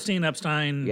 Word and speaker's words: Epstein: Epstein.